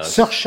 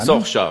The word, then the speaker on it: Saoirse: Saoirse Ronan. – Saoirse, voilà.